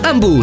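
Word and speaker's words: Hamu: Amu si scrive con l'H.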